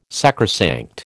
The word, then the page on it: sacrosanct: American English